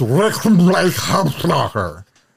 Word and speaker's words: Rickenbacker: It's pronounced Rickenbacker, Steve. Steve doesn't know how to pronounce Rickenbacker.